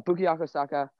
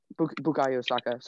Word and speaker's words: Bukayo Saka: Uh, Bukayo Saka is a um, Bukayo Saka. Sorry, I said his name wrong.